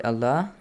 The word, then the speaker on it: Allah: So the lam in the word Allah right. – is pronounced with an ah sound.